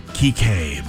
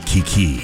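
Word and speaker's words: Kiké: Also, I know his name is pronounced Kike, but I miss the days when I called him Kiki.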